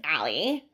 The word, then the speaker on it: Ali: Oh, my name is Allie. – Allie. – Yeah. – How do you spell that, Allie? – A-L-I. – A-L-I. – Yeah. – Okay. Like Ali, like Muhammad Ali.